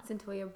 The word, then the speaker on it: Cyntoia: Already with the case previous, what was it, Saitonia, how do you say her name? – Cyntoia Brown, freeing her, or being involved with freeing her.